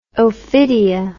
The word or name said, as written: Ophidia